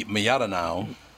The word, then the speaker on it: Mlodinow: it could be Miyadinow. Uh, it's an L. I know, but sometimes it's pronounced with y.